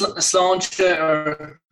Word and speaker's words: Sláinte: All right, before I get any further, am I saying Sláinte wrong? Because I have Irish heritage, but I don't know a lot of people that have lived in Ireland. So is that the correct pronunciation, Sláinte? – Yeah, that's Sláinte, Sláinte is how you'd say it.